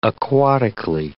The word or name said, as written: aquatically